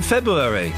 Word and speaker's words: February: People don't pronounce the first R in February.